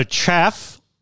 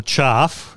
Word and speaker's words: chaff: So chaff, or chaff, as we from Adelaide pronounce it,